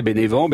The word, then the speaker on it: Bénévent: On arrive à Bénévent ou Benevento, selon si on prononce... – En français, Bénévent, Benevento.